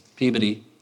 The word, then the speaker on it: Peabody: Peabody.